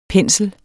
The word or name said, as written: pensel